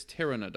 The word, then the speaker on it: Pteranodon: Did you look up the pronunciation for that? – Because I did. It's Pteranodon.